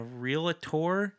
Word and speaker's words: Realtor: So it's two syllables. Realtor. Try it.